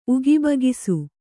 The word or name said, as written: ಉಗಿಬಗಿಸು